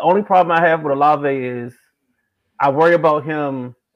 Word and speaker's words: Olave: It's Chris Olave. Olave.